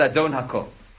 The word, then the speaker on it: Adon Hakol: Now, the way we pronounce it is Adon Hakol,